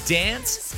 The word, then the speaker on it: DNCE: Got DNCE on the way. I think the band wanted them to be pronounced as Dance, because that's what they were going for, Dance, missing the A.